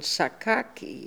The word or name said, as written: Sakaki